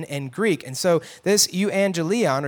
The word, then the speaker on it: euangelion: Originally, the word gospel or good news in the Greek was this word angelion, okay? Or angelion. You could say it kind of some different ways, right? Angelion or angelion. Angelion or euangelion, sorry, I'm saying it wrong. Euangelion, actually means good news.